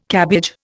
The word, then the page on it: cabbage: Indian